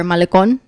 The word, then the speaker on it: malecón: Yeah, Mazatlán has the longest boardwalk or malecón in the world.